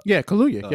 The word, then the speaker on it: Kaluuya: I'm probably going to butcher his last name. Kaluuya. – Kaluuya. Yep. Yeah.